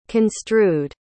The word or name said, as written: construed